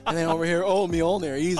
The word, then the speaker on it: Mjolnir: I pronouncing that right? Mjolnir? – Yeah, I thought you did.